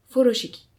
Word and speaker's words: furoshiki: Le terme furoshiki se prononce en japonais furoshiki et désigne aussi bien la technique que le carré de tissu.